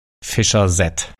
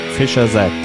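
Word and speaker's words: Fischer-Z: Fischer Z. – Fischer Z, das englische Wort für Fischkopf mit starkem regionalem Akzent ausgesprochen.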